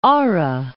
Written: Aare

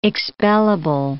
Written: expellable

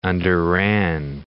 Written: underran

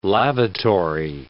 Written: lavatory

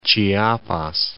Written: Chiapas

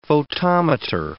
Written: photometer

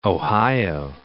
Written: Ohio

